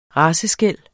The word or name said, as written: raceskel